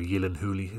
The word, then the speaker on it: Gyllenhaal: kind of time travel sci-fi thriller with Jake Gyllenhaal, or Gyllenhaal, this is actually how it's supposed to be pronounced. But you'll never have a career if your name is Jake Gyllenhaal, so Jake Gyllenhaal gets you a career.